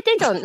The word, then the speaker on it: Daejeon: Am I saying it right? – Yeah, Daejeon.